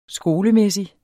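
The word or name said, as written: skolemæssig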